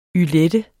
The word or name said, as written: ylette®